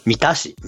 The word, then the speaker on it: 三田市: 今回指摘をされたのは、兵庫県の三つの田んぼの市と書いて何と読むでしょう。 – 三田市、三田市。 – って思うじゃん、うん。 – 多分私も三田市って読んだんですよね。 – うん。 – でも三田市なんですよ。